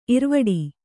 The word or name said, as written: ಇರ್ವಡಿ